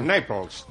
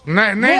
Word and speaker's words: Naples: Naples. – Naples.